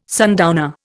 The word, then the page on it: sundowner: Indian